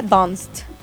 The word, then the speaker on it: Dunst: ¿Dunst? ¿Cómo se dice? Dunst.